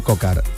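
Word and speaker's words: Coquard: Kokuar, seguramente no se dice así, pido disculpas, el ciclista francés. – Brian Kokar, que es precisamente el que está hablando, que imagino que no sé si se le entiende o no.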